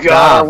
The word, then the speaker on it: Dorville: Well, as you corrected me for, because it's kind of spelt Dorville. But it's pronounced. – Oh God, Darville.